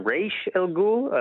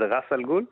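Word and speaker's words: Ra's al Ghul: זה בשלוש מילים, ומשום מה גם בחלק מהגרסאות מבטאים איזה רייש אל גול, אני לא יודע למה, אבל זה ראס אל גול.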